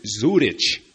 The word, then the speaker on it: Zurich: There are little specialty shops that make their own hand-dipped chocolates, and I can think of one right now called Zurich, spelled exactly like Zurich, Switzerland. Only down here, the Latins pronounce it Zurich.